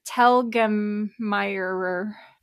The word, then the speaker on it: Telgemeier: Raina Tell Gregor. – Is that one whole name? – Okay. Raina, I had to look up the spelling. Mm-hmm. Raina Telgemeier.